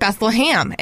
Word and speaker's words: Bethlehem: I grew up calling it Beth-LUH-HAM, and that's what it is.